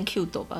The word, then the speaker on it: CUTiE: 嗯嗯，那这样的杂志呢，比如说像有日本有个杂志就叫做呃 Zipper，还有这个怎么念啊？Q Q 斗嘛，它也是 Q，应该是念 Q 斗吧？ – 对，C U T I E。